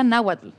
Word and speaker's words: náhuatl: Eh, él la ocupaba ella, o sea, ella era muy feliz narrando, pero también ella sabía náhuatl.